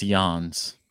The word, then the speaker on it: Jans: Is it Chris Johns or Jans with a soft J? I believe it's Jans.